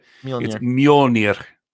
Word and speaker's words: Mjolnir: Yeah, I can't roll my R's, so I can't actually say the name of it. Mjolnir. That's how you say it.